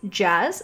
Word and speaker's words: Jazze: My name is Jazz, spelled J-A-Z-Z-E. There is a silent E, although everyone calls me Jazzy, so it's very confusing. But it is actually pronounced Jazz.